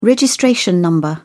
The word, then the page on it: registration number: British English